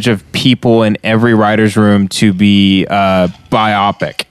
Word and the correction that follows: BIPOC: BIPOC. – It's pronounced BIPOC. Biopic. Yeah. It's like a biography.